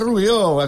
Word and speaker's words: Rubió: Hombre, la Montserrat Rubió, eh que sí? – Sí, amb accent a on, i ho sap tothom.